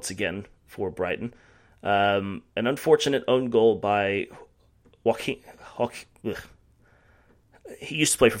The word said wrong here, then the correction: Joachim: I believe it's Joachim Anderson. – Is it? – Yeah. There we go, Joachim. Yeah. There you okay. – Go. – Yeah, because that's Danish.